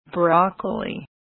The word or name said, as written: broccoli